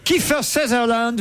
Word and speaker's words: Kiefer Sutherland: Kiefer Sutherland.